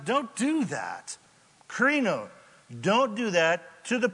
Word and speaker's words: krino: krino, K-R-I-N-O. Krino, which rhymes with Reno, and that's, I don't know what that means.